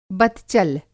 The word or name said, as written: बतचल